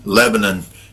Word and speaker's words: Lebanon: And as they begin to migrate out and the music community starts to collect around Murfreesboro, around Mount Juliet, around Lebanon, you know, and all these other places. It's pronounced Lebanon in Tennessee. It's Lebanon over across the ocean.